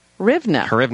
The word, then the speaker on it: Hryvnia: Hryvnia.